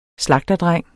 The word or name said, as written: slagterdreng